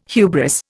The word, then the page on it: hubris: Indian